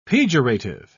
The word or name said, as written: pejorative